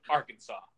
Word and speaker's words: Arkansas: How do you say Kansas with AR in front of it? Arkansas. Yeah, that's what I'm saying. Where's the SAW, motherfucker? Uh, uh, that's a good point.